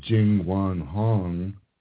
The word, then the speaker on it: Jingwan Hong: J-I-N-G-W-A-N-H-O-N-G.